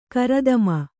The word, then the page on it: ಗರುತ್ಮ: Kannada